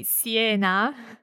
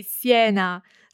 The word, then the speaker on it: Siena: Well, I say Siena. But Silvia would say Siena, so yep. Different ways of pronouncing the letter E in Italian.